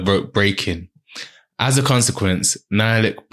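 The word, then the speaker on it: Nhialic: Okay, so it's spelled N-H-I-A-L-I-C-K. I would say Nialik. – Nialik.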